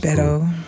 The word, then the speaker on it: Beto: I think he, um, you know, um, Beto. Is it Beto or how, how is it? – Beto.